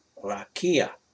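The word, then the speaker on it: Rakia (רקיע): In Hebrew, the word firmament or heaven is Strong's H7549, pronounced Rakia.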